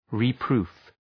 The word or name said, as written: reproof